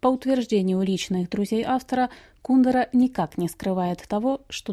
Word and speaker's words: Кундера: Русскому читателю Милан Кундера, в России его фамилию произносят именно так, с ударением на второй слог,